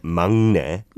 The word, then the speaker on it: 막내: So just taking that into account, you gave us the pronunciation tip. It's not 막내, but 막내. – 막내, always 막내. – 막내, 막내. – 막내. – The 기역 받침 and the 니은 of the next sound kind of blend in together.